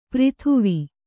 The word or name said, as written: ಪ್ರಿಥುವಿ